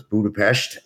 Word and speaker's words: Budapest: The S is pronounced sh. So it's Budapest, and we have about 120 folks in Hungary.